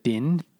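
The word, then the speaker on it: Dynne: But it's spelt weird. Like the Dynan, and it's D-Y-N-N-E, but Din, D-I-N is the noise, like a bunch of, this is the word for a bunch of loud, obnoxious sounds.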